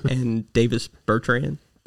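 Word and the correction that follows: Bertāns: Davis Bertans.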